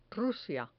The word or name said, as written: rusia